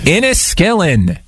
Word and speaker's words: Inniskillin: Yeah, but it's called a certain, I don't know if I'm saying it correct, so insulin, right? Is that how you say it? Iskinolin or something like that? Insulin? – Well, I mean, if you're going to chug insulin, what happens in Vegas and so forth? Inniskillin. Close enough.